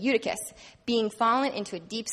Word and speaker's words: Eutychus: Eutychus, thank you.